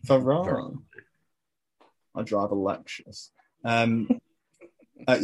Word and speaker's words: Varane: A problem if they sign Varane and Sancho.